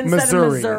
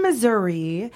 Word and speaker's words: Missouri: No, Missouri. It's not Missouri. God, it's Missouri. – Just like Des Moines. Really. – Yeah, right. – Right. Illinois. – Go ahead. – So in Missouri, yes.